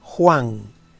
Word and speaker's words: Huang: Por lo que los 2 hexagramas relacionales del antagonista serán Huang, que se escribe H-U-A-N-G,